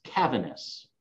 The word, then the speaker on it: Cavaness: It's not the easiest to pronounce. It's Cavaness.